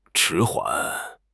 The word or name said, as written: 迟缓